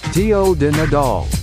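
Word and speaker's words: Tió de Nadal: Tio de Nadal.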